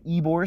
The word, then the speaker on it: Ybor: So, yeah, then final day, I went to Ybor City, which is spelled Y-B-O-R.